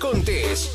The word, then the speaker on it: Contés: Esto es Mi Rutina de Trabajo con Miguel Contés, con acento en la E.